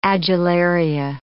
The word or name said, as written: adularia